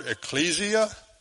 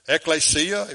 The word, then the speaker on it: ekklesia: And so when you look at the church, the word church in the New Testament, it is the Greek word ecclesia, ekklesia, if you want to say it like that.